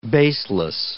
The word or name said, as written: baseless